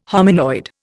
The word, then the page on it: hominoid: Indian